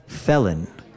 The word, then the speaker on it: fällen: Yeah, it's fällen, two L's, so short vowel. – And from earlier we had a silent H word that sounds similar. Fehlen, F-E-H-L-E-N, which we know is the long vowel.